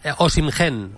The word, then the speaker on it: Osimhen: ¿Cómo se dice? ¿Osimhen? ¿Es? – Es Osimhen. – Osimhen, es que claro.